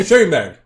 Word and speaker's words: Schenberg: Schenberg. É esse mesmo? É. O nome é S-C-H-E-N-B-E-R-G.